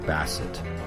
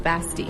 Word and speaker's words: Basti: Basset. – No, Basti. – Basti. – Like bestie, but like...